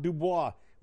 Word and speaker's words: DuBois: Don't tell me Colonel is spelled C-O-L-O-N-E-L. No. – Colonel? – Cologne. Listen, we don't play that shit here in Pennsylvania. We have a city that's spelled D-U-B-O-I-S. It's not Dubois. We say it the way it's meant to. Dubois.